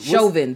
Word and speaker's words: Chauvin: But what's Chauvin. Thank you, Nikki. – Chauvin.